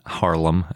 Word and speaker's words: Haarlem: So this one was discovered in 1855 in Germany near the Dutch town Harlem, I think is how you say it. It's like Harlem in New York, except with two A's. I'm not sure what that does to the pronunciation, but it wasn't described until 2 years later in 1857.